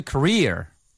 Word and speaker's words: career: 네, 이거 career 아니에요. C-A-R-E-E-R. 발음 주의하세요. – Career. – 네, career 하셔야 돼요. Carrier 그러면은 보균자 내지는 나르는 것이란 뜻입니다.